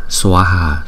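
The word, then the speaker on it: Svāhā: Hai chữ A có dấu gạch ngang trên đầu cho nên chúng ta phải đọc rõ ràng ra là Swaha, Swaha.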